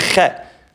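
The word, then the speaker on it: Chet (חטא): The Heilige Baal Shem Tov taught us that the word chet is ches tes aleph. And the Aleph is silent.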